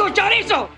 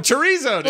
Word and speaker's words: chorizo: Oh, chorizo, dude. – Oh, chorizo, yeah. – That's right. Yeah, she had your pronunciation. I was impressed. Mm. That was great. Which is to say the real one, the actual pronunciation.